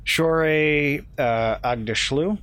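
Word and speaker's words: Shohreh Aghdashloo: Shore Agdashloo, is that how you pronounce it? I think so. – Yeah, that's right.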